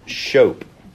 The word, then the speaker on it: scop: The word used the most in Beowulf to describe God's creativity and his creative action is the verb shope. Shope, it's Old English. It's actually spelled S-C-O-P, but it's pronounced shope. Which rhymes with soap, coincidentally.